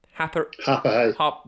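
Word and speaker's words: Harpurhey: You have to say it like that when you come from Happerhay. – Happerhay. – Because you actually say, like, Harper Hay.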